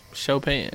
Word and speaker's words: Chopin: Pronounce it like S H O. – Oh, okay. – Chopin. Yeah. French Not Chopin.